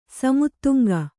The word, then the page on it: ಸೀಮೆತೆಂಗು: sīme tengu